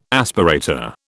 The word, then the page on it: aspirator: British English